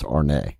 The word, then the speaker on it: ARN: There's number one, which is L-I-L, the highest and most outermost. A-R-N, which is supernatural threshold. It's pronounced Arne. Zodame, Z-O-M. Deep self-knowledge, getting visions from the abyss. Podax, P-A-X, form the beginning to emerge from pure spirit.